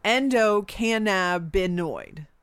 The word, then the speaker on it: endocannabinoid: Endocannabinoid.